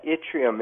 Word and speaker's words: yttrium: Yeah, yttrium. It's pronounced a couple different ways, I guess, but yttrium.